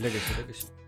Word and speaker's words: Legacy: Будет называться Lost Legacy, или Legacy, как оно правильно читается. – Legacy.